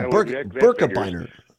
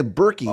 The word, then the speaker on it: Birkebeiner: So, yeah, Birkebeiner. Some people say Berkey. Oh. I saw it as Birkebeiner or Birkebeiner, but I think most people here pronounce it Birkebeiner.